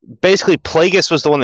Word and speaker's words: Plagueis: Yeah, Plagueis.